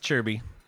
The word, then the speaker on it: Kirby: Spelled Kirby, pronounced Chirby.